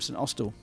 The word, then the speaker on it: St Austell: Did you see I pronounced that correctly this time? St Austell.